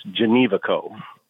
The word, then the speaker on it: Genevico: It sounds easier than what it ever looks. It's Genevico.